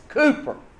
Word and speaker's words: Cowper: His name is spelt C-O-W-P-E-R, Cowper. I have found a pronunciation key where they say his name is pronounced Cooper, C-O-O-P-E-R. But I've been calling him Cowper for so long, I'm not going to change.